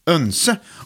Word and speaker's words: oense: Önse. O-E blir ju egentligen ö, vet du. Bjoer till exempel. Mm. Så oense uttalas önse på svenska.